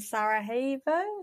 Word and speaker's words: Sarajevo: and one's in Sarajevo.